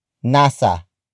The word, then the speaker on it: nasa: This features a predicate that begins with a preposition word, nasa. Nasa. Nasa. Nasa is spelled as N-A-S-A.